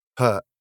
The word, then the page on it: hurt: British English